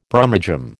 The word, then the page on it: Brummagem: American English